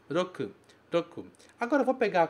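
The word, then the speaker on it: roku (六): Este caractere significa 6 e se pronuncia roku.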